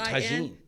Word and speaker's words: tagine: T-A-I-G-I-J-I-N. – Oh, tagine.